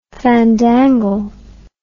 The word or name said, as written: fandangle